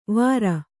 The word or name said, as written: ವಾರು